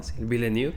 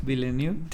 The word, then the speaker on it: Villeneuve: Villeneuve. Villeneuve.